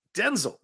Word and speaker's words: Denzel: It's actually pronounced Denzel. Denzel, not Denzel.